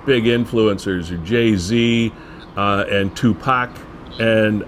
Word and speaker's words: Tupac: It is Tupac.